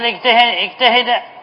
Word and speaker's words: اجتهد: قال إلا ولا ينطق بها إلا إذا بدأنا بها الكلام، مثل اجتهد، مثل اجتهد محمد، اجتهد محمد هو نطقناها مكسورة، لأن الأصل في نطق همزة الوصل أنها تنطق ماذا؟ مكسورة، وتفتح في أل، وتضم إذا كان الحرف الثالث من الفعل مضموما.